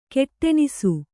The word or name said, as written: ಗೊಡಣಿಸು